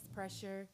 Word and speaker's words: Preshr: Okay, so my name is Pressure. You could spell that P-R-E-S-H-R.